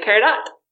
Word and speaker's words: peridot: Our French professor, G.A., always called it Peridot. And but we're pronouncing it the French way, Peridot, by drawing but he pronounced it the English way, Peridot.